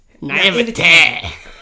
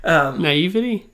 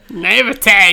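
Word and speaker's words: naïveté: Naivete. That's how you pronounce naivete. – Naivety? – Yeah, that, but you don't say it like that. You say it like I said it. – Naivete.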